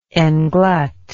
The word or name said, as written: englut